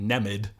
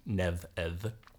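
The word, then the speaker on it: Nemed: It's N-E-M-E-D. So those who haven't taken up Old Irish would probably pronounce it Nemid. The M and D are in fact going to be lenited to Nev-Ed.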